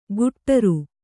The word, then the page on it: ಗುಟ್ಟರು: guṭṭaru